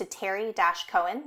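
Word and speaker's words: Terri Koen: Support this podcast with a donation via Venmo to Terry-Cohen, that's spelled Terri-Koen, and telling your friends and family.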